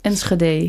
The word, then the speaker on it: Enschede: Ik spreek Enschede altijd verkeerd, dus hoe zeg ik dat goed? Enschede? – Enschede.